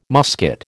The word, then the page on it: musket: American English